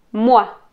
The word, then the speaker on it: moi: Cuando vean las vocales escritas OI juntas y OY juntas, van a pronunciar WA. Ua, como si pronunciaran una U y una A. Ua. Como froid, froid, soif, soif y moi, moi.